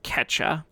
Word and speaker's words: Quechua: I believe it's pronounced Quechua, um, spelled Q U E C H U A.